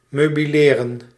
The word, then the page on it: möblieren: /møˈbliːʁən/; [møˈbliːɐ̯n]; /møˈbliːrən/